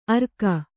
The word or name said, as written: ಅರ್ಕ